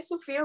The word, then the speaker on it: Sophia: And So I go by Sophia Rayne on every streaming platform. But the way that my name is spelled is S-O-P-H-I-A, and then Rayne is R-A-Y-N-E.